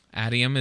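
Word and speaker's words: Adium: Atium is exactly how I rhymes with stadium.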